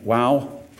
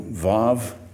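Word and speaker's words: Waw: Psalm 119, section Wow, or if you want to use modern Hebrew, Vav, but the classical pronunciation is Wow, verses 41 to 48.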